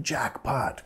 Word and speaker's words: jackpot: Nota, vamos a acentuar la primera sílaba. Jackpot, jackpot.